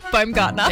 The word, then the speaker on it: Baumgartner: Baumgartner.